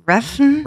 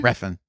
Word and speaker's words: Refn: I am going with 2016's Neon Demon, directed by Nicholas Winding Refn. – Refn, yeah. – I said it right.